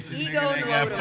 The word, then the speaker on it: Ego Nwodim: Anyway, It's a female actress, the comedian Ego Nwodim. I believe that's how you properly pronounce her name. Ego Nwodim.